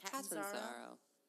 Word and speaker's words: Catanzaro: And the kicker, Chandler Cantuzaro. – You just wanted to say his name. – No, I can never say it. – Catanzaro. – Catanzaro. – No, it's Catanzaro.